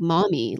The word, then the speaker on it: Mahmee: I just realized saying it, it's spelled M-A-H-M-E, but it sounds like Mahmee, like M-O-M-M-Y.